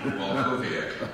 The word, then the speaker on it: Walkowiak: Walkowiak.